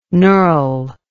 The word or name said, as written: nurl